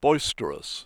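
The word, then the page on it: boisterous: British English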